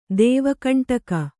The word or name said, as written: ದೇವಕಂಟಕ